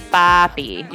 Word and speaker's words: papi: Okay, number one, how do you pronounce the last word in the name of this song? – Papi. – Papi, with a long A, right? – Yeah.